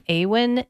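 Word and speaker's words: Eowyn: Awen Ivey.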